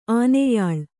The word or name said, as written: ಆನೆಯಾಳು